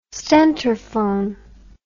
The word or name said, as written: stentorphone